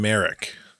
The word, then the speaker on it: Marek: Galen Merrick is M-A-R-E-K. Merrock is M-A-R-R-O-K. But they sound similar, very similar.